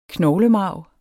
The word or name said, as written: knoglemarv